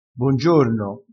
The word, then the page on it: bongiorno: dialettale